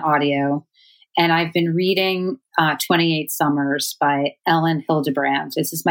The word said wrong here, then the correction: Elin: my first Ellen Hildebrand or Elin. I'm not sure how to pronounce her name. – And I think it's Elin. – Elin.